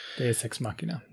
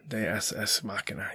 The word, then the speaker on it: deus ex machina: Deus, how do you pronounce that? It's Latin. – Deus ex machina. Deus ex machina.